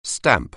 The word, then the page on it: stamp: anglais